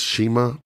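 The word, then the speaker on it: Cima: I think it's Shima, pronounced Shima, but it's C-I-M-A, who is a legend in Asia, but also beat Christopher Daniels at the last event.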